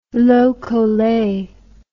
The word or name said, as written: lokole